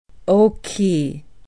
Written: okee

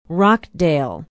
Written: Rockdale

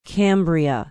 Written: cambria

